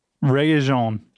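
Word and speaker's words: Regé-Jean: Let's see how to pronounce. Rejon. I'm going to say it's Rejon. Rayon. Aha, uh-huh, I was pretty close.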